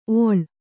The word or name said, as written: ಓಳಿ